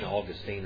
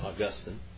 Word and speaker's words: Augustine: Calvin refined the arguments of Augustine, which is called St. Augustine or St. Augustine, however you want to pronounce it.